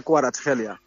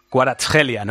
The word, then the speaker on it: Kvaratskhelia: Sería Kvaratskhelia. – Kvaratskhelia, ¿no? O sea, Mansilla, aplícate. – Otra nueva. – J, ¿eh? J, ya sabes que la KH allí es J.